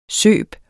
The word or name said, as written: søb